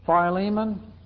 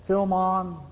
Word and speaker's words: Philemon: Philemon or Philemon, depending on how you want to pronounce that book of the Bible, that's not a big issue in that short little book.